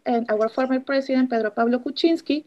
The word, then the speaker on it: Kuczynski: the Kuczynski presidency? – Yeah, Kuczynski, Pedro Pablo Kuczynski.